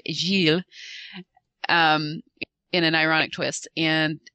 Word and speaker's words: Gilles: Sarah is busy romancing a Frenchman who, it's, help me out here, it's pronounced, or I'm sorry, it's spelled G-I-L-L-E-S. How is that pronounced? – Gilles. – Yeah. As in, you know, Jill.